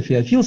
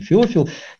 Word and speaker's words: Феофил: Евангелие же от Луки по тщательному исследованию, как помним, сам Лука пишет, пишу тебе, достопочтенный Феофил, кто-то Феофил ставит ударение, наверное, правильный Феофил, неважно.